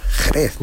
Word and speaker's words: Jerez: Pues en aquella época la palabra Jerez, escrita también con X, no la pronunciamos como tú y yo ahora. Jerez, no. Decían sherry.